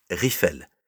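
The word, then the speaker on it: Rieffel: Tout d'abord, je tiens à préciser que mon nom se prononce Riffel. En effet, le E entre le I et les deux F est bel et bien muet.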